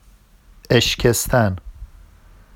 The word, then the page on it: eʃcestan: Vafsi